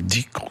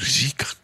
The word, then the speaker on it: dhikr: Les pratiques soufis, qu'on appelle dhikr ou zikr, comme on prononce en persan.